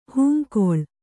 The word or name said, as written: ಹಂಗಳಿ